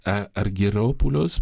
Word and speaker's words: Argyropoulos: c'est Yanis Argyropoulos, pour le prononcer à la grecque,